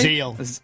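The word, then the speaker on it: Zeile: Is it A, Juan Uribe, B, Todd Zaley? Is that how you say it? – Zeal. – Todd Zeal? Sorry.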